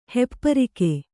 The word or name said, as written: ಹೆಪ್ಪರಿಗೆ